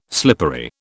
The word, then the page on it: slippery: British English